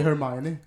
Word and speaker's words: Hermione: Det är Hermione.